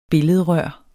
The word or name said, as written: billedrør